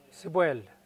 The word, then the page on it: sipwel: Mokilese